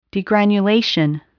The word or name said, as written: degranulation